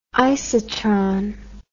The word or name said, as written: isotron